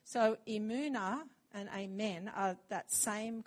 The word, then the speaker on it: emunah: the familiar word, and the word is actually, I'm going to see if I can say it right, emunah, E-M-U-N-A-H, but you say the mu in the middle, emunah. And E-M-U-N actually is also from where the word amen comes from, emun, amen.